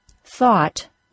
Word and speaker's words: thought: When the letters O-U-G-H are followed by the letter T, the O-U-G-H is usually pronounced as the A-W sound, aw. That's the sound in the word dog. So the words thought, brought, and fought all rhyme, and the A-W sound, aw, is the vowel sound for all of them. Let's move on to the word through. Through, T-H-R-O-U-G-H, is pronounced the same as through, T-H-R-E-W.